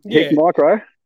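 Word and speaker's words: Hikmicro: So for myself, I've just bought a I can never say it properly, Himicron or whatever they call it. Himicron, we'll say it. And Hick micro. – Himicro, there you go.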